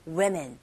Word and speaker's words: women: 자, 이때 woman, 요건 단수의 철자 W-O-M-A-N이죠. Woman. 근데 여기서는 지금 복수 형태가 나왔으니까 발음이 달라집니다. Women. Women. 요게 women. 요게 복수 형태고 woman. 요게 단수 형태. 꼭 구분해서 알아두셔야 돼요.